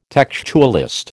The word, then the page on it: textualist: American English